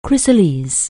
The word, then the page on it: chrysalis: anglais